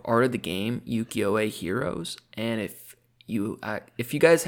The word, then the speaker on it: ukiyo-e: Japanese woodblock prints, specifically the ukiyo-e style.